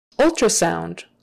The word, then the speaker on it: ultrasound: That TR is often pronounced more like a CHR. Because doing a proper T is a little trickier. Ultra, ultra. Typically in regular conversation, people will turn that T into a CH kind of a thing. Ultra, ultra, ultrasound, ultrasound, ultrasound. And of course the sound, O-U-N-D is oun, oun, sound.